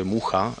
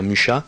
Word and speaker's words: Mucha: Muja eh, en, la creación del modernismo, a la francesa se pronuncia Mucha, y dejan una impronta en eh, el, así en el arte mundial, a través de unos cuantos personajes.